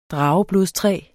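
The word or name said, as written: drageblodstræ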